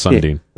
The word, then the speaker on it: Sundin: Matt Sundin on there. – Hey, and... good. I was glad you pronounced his name because I was going to have... – Yeah, it's Sundin.